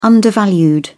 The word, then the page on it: undervalued: British English